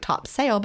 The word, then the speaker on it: Topsail: It looks like Topsail, but they say Topsail because I've been there.